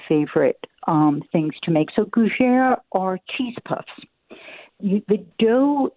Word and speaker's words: gougères: Dori, I saw a recipe you had in the New York Times, and correct me if I'm pronouncing it wrong, gougères? – Very nicely done. – Nice. – Yes, gougères.